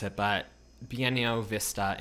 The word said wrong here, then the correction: Buena Vista: International, which is Disney. – Buena Vista.